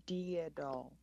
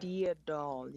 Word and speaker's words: Deadol: It's Deadol. – Deadol. Did I say it right? – Deadol, yeah.